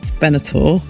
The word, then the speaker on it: Benatar: So your Goosebumps track I want to start with, which is Love is a Battlefield by Pat Benatar.